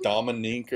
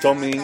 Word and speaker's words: Domingue: I thought it was like Dominique or something. But Domingue. – I thought so too. I hear it's Louis Domingue. Okay, Domingue. Right. Okay, so Domingue is 7-0-3 as a starter,